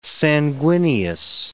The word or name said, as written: sanguineous